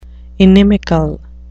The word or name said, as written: inimical